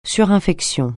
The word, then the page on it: surinfection: French